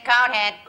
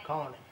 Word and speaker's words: Conehead: Cohen. Is that spelled with an H? – Conhead. – The name is Conehead. – Conehead.